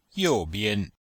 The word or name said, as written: you4bian5